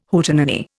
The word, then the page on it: Hootenanny: Indian